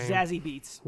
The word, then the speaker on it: Zazie Beetz: Zazie Beetz.